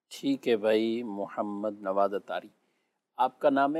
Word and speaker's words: محمد: تو محمد کا جو تلفظ ہے وہ محمد یہ ہے.